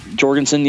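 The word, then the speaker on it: Jourgensen: Jorgensen, yeah.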